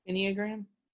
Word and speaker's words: Enneagram: Do you pronounce it Enneagram or Enneagram? – Enneagram. – Enneagram. Okay.